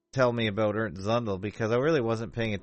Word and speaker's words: Zundel: But how do you say his name again? – Zundel. – Right. Is that like tsunami? You know? – Yeah, something like that, yeah.